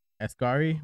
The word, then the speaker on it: Asghari: So yeah, Spencer responded to the pop star's engagement to longtime boyfriend Sam Asghari with some meaningful advice.